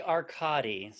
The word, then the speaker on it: Arcadi: It's actually Arcadi. – So Arcadi, okay. – I think more Italian pronunciation and less Greek.